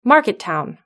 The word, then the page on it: market town: British English